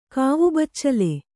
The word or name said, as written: ಕಾವುಬಚ್ಚಲು